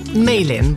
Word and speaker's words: Malin: Well, Malin, but I'm...